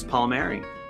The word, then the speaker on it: Palmieri: My name is Chris Palmieri.